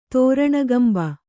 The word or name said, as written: ತೋರಣಗಂಭ